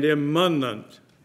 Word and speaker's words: immanent: That's immanence. Young people, there are two words that sound so close. One is imminent and one is imminent. And I pronounce those differently, but you might not even have heard that. Imminent and imminent. Imminent and immanent. You almost have to say it slowly to necessarily catch it by ear.